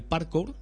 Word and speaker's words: parkour: porque practican el parkour, no sé si lo he dicho bien. – Sí.